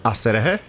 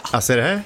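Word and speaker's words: Aserejé: Men hur skulle du uttala det här? – Asserehe? – Ja. – Asserehe? – Ja.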